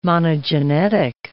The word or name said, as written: monogenetic